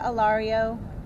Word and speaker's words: Alario: So my name is Celia Alario, that's C-E-L-I-A. My last name is spelled A-L-A-R-I-O.